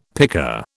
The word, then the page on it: picker: British English